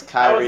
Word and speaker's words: Kyrie: Kylie Irwin. Irving. – Kylie Irwin. – It's Kyrie.